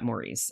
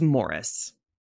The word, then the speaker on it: Maurice: Pronunciation police, because it's I would have normally pronounced that Maurice, and if it was an American film, that is how I would say it. It's Morris. I have been married to an English man for almost 21 years. – Like the cat. – Yes, it's spelled the way that we would spell Maurice, but both spellings in England are Morris.